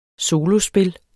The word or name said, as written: solospil